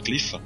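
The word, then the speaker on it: Qliphoth: Se escreve Clifote, mas se fala Clifa.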